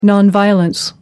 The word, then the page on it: non violence: American English